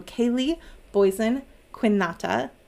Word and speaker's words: Kaleigh Boysen-Quinata: Today we are going to be talking to Kaleigh Boysen-Quinata.